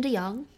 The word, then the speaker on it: DeYoung: Kevin DeYoung.